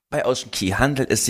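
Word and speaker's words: Ocean Cay: Ja, auf den Bahamas wird K wie Key ausgesprochen und deswegen disziplinieren wir uns nun schon sehr, sehr lange, dass wir halt eben sagen Ocean Key. Klingt auch viel schöner als Ocean K, muss man ja auch nochmal dazu sagen.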